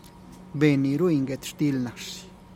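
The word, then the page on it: ben ruin guet xtilih nash: Teotitlán del Valle Zapotec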